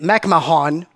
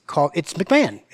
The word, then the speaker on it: McMahon: It's spelled in a way it doesn't sound, right? M-C-M-A-H-O-N. You would think McMahon, but it's McMahon.